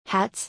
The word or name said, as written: Hats